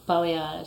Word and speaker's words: Balayage: How you pronounce it? Is it Balayage? – Balayage. – Balayage. Balayage. Clearly, I don't know how to say it.